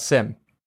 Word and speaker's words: SIEM: So today, we're talking about SIM. S-I-E-M. SIM, yes. with a silent E.